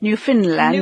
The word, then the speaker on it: Newfoundland: To give you an example, in Newfoundland, and it's pronounced that way even though it's spelled Newfoundland. – But it is pronounced Newfoundland.